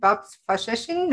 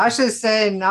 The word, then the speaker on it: Faseesin: And today I'm really excited to have my new friend, Babs Faseesin. No, I pronounced it wrong. Tell me, how do you pronounce your name again, Babs? – It's Babs Faseesin. Thank you. – Faseesin, all righty.